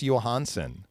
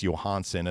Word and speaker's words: Johansson: he is now back in Washington as Marcus Johansson and the hard J has been left on the airplane.